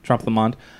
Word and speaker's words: Trompe Le Monde: What is it about Bossa Nova and Trompe Le Monde? Is that how you pronounce it? – Mm-hmm. – Trompe Le Monde.